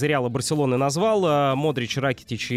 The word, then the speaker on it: Ракитич: Естественно, Лука Модрич, Иван Ракитич, как и известны хорватские имена, э, вернее, имена и фамилии, да, здесь, как правило, ударение на первый слог, поэтому не Ракитич, не Ковачич, там, не Брозович или там э, Манжукич, а Ракитич, Кова... Ковачич, Брозович и Манжукич.